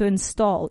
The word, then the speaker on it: install: Number 17, instalar, to install, to install. Even though it's I-N-S-T-A-L-L, esa A suena como una O. Install, install, to install.